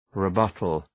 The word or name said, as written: rebuttal